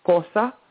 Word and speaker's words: Xhosa: She speaks and sings some of the lyrics in her native tongue, which is pronounced Xhosa.